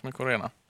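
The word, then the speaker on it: Mycorena: Det är ju svamp på latin, va? eller nästan, eller? – Mykorena. Ja, ja precis. Mykorena.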